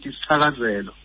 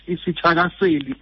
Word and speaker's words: isithakaseli: They mispronounce this thing later and call it isithakazelo. Actually, it's isithakaseli because I'm so happy to see you.